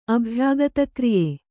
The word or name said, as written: ಅಭ್ಯಾಗತಕ್ರಿಯೆ